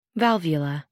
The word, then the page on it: valvula: American English